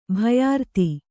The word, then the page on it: ಭಯಾರ್ತಿ: kannada